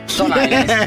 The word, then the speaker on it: Stone Island: Questi gins della Stone Islands. – Stone Island. – Ma perché scrivono? Scrivono come si legge. – Si chiama così, Stone Island.